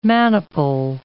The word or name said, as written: maniple